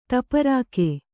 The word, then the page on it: ತಪರಾಕು: Kannada